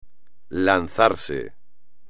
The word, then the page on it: lanzarse: espagnol